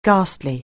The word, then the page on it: ghastly: anglais